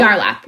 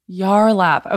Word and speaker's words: Yarlap: And as far as, so with this device, I'm sorry, is it Yarlap? How do you say it? – Yarlap, Yarlap. – Yarlap. Okay.